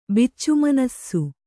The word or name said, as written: ಬಿಚ್ಚುಮನಸ್ಸು